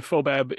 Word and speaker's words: FOBAB: And FOBAB is, for people who don't know? – The FOBAB is the Festival of Wood and Barrel Aged Beer. The wood W is silent.